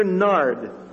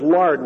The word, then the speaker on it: nard: Mary therefore took a pound of ointment of pure nard. I mispronounced that as lard one time years ago.